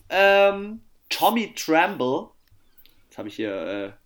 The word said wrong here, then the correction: Tremble: Tommy Tremble von Notre Dame.